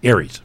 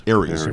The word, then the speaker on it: ARES: ARES is pronounced? – Aries. – Aries. – Right.